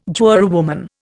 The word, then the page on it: jurywoman: Indian